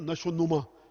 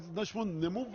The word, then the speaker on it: نشو نمو: نشو نما، یہ جو نما ہے نا جو آپ لوگ نشو نمو ہے اس کا صحیح تلفظ، لیکن اردو میں ہم کہتے ہیں نشو نما،